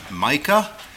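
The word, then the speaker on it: Micka: And fourth will be Sister Mary Virginia Micah. I do know how to spell that because she spelled it for me. It's M-I-C-K-A, but it's pronounced like the biblical prophet's name.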